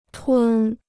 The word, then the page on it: 吞: tūn